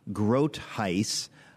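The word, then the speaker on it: Groothuis: And then after that, we have a Denver Seminary professor, Douglas Grotheis. I'm pronouncing his name correctly because he told me.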